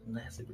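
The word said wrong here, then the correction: Labissière: Scal Labassiere.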